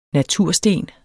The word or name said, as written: natursten